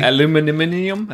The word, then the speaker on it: aluminium: Aluminium, as the British say.